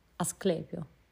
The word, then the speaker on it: Asclepio: Asclepio, Ascelpio, Asclepio, sono abbastanza sicura, o Esculapio, o Esculato, non so, Esculapio.